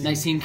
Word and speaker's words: Nicene: Nicene Creed.